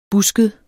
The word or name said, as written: busket